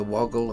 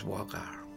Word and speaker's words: Waugal: So when you hear people saying the woggle or the waggle, maybe they're not wrong, but the correct way to say it is Waugal.